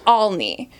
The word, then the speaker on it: Olney: It's pronounced Olney. – Olney. – Which is O-L-N-E-Y.